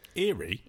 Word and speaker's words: Ériu: Eerie.